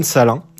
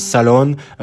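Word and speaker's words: Salaün: avec Tidjane Salin. J'espère que je le prononce bien, dans le sens où beaucoup le prononcent Salon, moi je le prononce à la française, on va dire.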